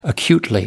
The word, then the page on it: acutely: American English